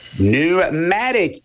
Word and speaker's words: pneumatic: Pneumatic is correct, with a silent P at the front.